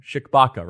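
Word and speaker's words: Tshibaka: who has a race tomorrow against Shikbaka, right? No, the T is silent. It's kind of like Django Unchained. The D is silent.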